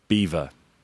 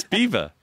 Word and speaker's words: Belvoir: It's called Beaver Castle, and it's spelled Belvoir. So the French would say, so let me explain why you pronounce it differently to the way that it's spelled.